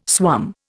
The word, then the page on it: swum: Indian